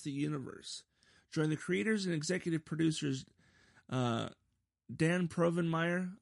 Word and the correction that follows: Povenmire: Or Povenmire. There's no R there.